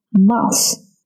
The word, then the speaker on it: mouth: And into Tabby's mouth. Mouth，嘴巴，这个嘴巴的发音和小老鼠的发音不太一样哦。 Mouth，要把你的小舌头轻轻地伸出来一些，放在两排牙齿中间，这样发音。